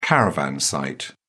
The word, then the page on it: caravan site: British English